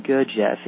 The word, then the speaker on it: Gurdjieff: So just a little bit then on Gurdjieff. It's spelled G-U-R-D-J-I-E-F-F.